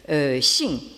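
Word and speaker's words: xing (性): Alors, donc, toute la discussion tourne autour d'un mot qui s'écrit et se prononce en chinois moderne, vous l'avez en haut de la diapositive à gauche, donc « xing ».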